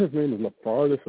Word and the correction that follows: Karlaftis: or something crazy shit. – Yeah, it's, I can't pronounce that shit, but it's Karlaftis. George Karlaftis.